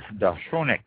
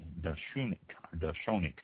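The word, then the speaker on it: De Chaunac: Next one, Dachshunac, D-E, and then C-H-A-U-N-A-C, Dachshunac. I've already found Dachshunac, and Dachshunac is also another pronunciation.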